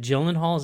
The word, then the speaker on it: Gyllenhaal: Is it Gyllenhaal? Is that how you pronounce it? – Yeah. Yeah.